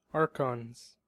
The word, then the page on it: archons: American English